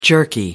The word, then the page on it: jerky: American English